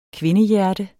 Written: kvindehjerte

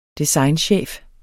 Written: designchef

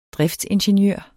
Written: driftsingeniør

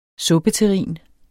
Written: suppeterrin

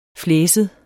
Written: flæset

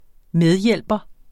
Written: medhjælper

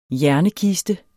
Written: hjernekiste

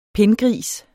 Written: pindgris

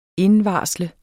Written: indvarsle

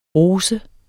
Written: rosa

